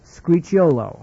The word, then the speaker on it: Scricciolo: The three others are, please note, Luigi Scricciolo. I'm going to spell that S-C-R-I-C-C-I-O-L-O.